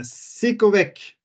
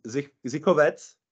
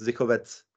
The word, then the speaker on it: Zichovec: Oh, we, yeah, it's actually pronounced Zikovec. Zikovec. So uh, the, ah. the, yeah, the ch in Czech language is like uh, x in uh, Mex Mexico. Okay. So it's uh, yeah. uh, zicho. Uh, c is pronounced like t, so it's Zikovets.